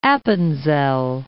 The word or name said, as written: Appenzell